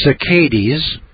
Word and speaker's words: saccades: If you're poisoned by mercury from any other source other than vaccines, you may suffer from visual impairments, inaccurate slow saccades, I guess that's the way to pronounce that, S-A-C-C-A-D-E-S, decreased rod function.